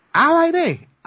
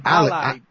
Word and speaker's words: Allied: Allied.